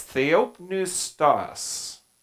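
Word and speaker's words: theopneustos (θεόπνευστος): And how do you pronounce that? Anyone want to hazard a guess? Okay, well, if you read the transliteration there, it's theopneustos. Okay, theopneustos. Okay, and let's read it.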